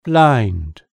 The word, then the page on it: blind: British English